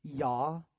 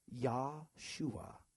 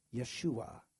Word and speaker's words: Yeshua: And Jesus in Hebrew is said like this, Yahshua. Yahshua. Yeshua. It's a lot like Joshua in the English.